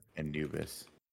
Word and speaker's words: Anubis: Anubis. We're saying that right, right? – Yeah, we're mm-hmm. nailing it.